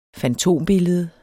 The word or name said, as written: fantombillede